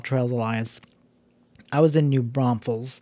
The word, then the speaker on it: New Braunfels: I know people that live in New Braunfels and for whatever reason put an S in the middle and pronounce it New Braunfels, and it is like nails on a chalkboard with somebody tapping a pen while waiting on you. It's the worst sound, and I'm always giving people a hard time when they live in town when they pronounce it like that.